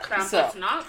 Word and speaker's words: Krampusnacht: And that night is called Krampusnacht. – Okay, yes. I didn't know how to pronounce that. – Krampusnacht.